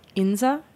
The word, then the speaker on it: Insa: Tell me the actual pronunciation. – Inza.